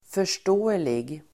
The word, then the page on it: förståelig: för_st'å:elig